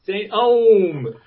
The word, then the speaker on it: St. EOM: And then finally, from Waiting for UFOs, St. Eom. Is that how you pronounce? – St. Om. – Om. Okay, they got it. It's spelled E-O-M here. – Yeah. And maybe it is E-O-M. I pronounce it Om.